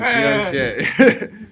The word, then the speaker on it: Piaget: Piaget.